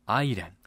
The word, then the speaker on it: 愛人: それは田中豊先生のアイレン。田中は漢字で、豊はカタカナ、アイレンは愛人と書いてアイレンと読みます。